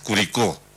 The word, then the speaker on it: Curicó: O sea, se escribe igual, pero se marca de otra manera. La de la séptima región es Curicó, y acá hay un sector al lado del Ebu que se llama Curico, maestro.